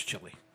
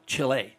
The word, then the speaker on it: Chile: No one ever attacks Chile. Or Chile, I should say. That's the way it's pronounced.